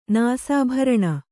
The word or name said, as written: ನಾಸಾಭರಣ